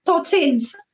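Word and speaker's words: Totsiens: I think, is it Tutsins? Is that how you say it? Is that see you later? – Tutsins, yeah.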